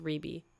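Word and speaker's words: Rebbie: And R-E-B-B-I-E. I know it looks like Rebe, but it's pronounced Rebe.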